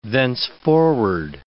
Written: thenceforward

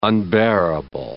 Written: unbearable

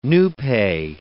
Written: Nupe

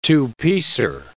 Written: two-piecer

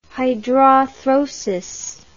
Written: hydrarthrosis

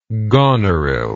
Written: goneril

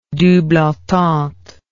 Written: double entente

